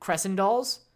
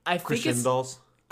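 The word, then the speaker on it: Crescendolls: I just realized how it's supposed to be pronounced. In my mind, I always pronounce it Crescendals. – Crescendals? – Crescendals. – Yeah. – That sounds so much better. – That makes so much sense. Because crescendo, right. The word crescendo is literally right there.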